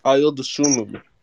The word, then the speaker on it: Ayo Dosunmu: Ayo Dosunmu.